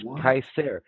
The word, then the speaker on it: Caesar: Oh, it is Kaiser. – Okay. – Yeah, in Latin, it was always a hard C.